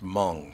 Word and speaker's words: Hmoob: I still want to know how H-M-O-O-B is pronounced Hmong.